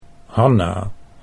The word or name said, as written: Hanna